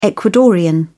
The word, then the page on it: ecuadorian: British English